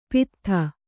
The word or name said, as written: ಪಾಥೋದಿ